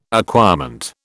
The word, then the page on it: acquirement: British English